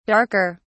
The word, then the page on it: darker: British English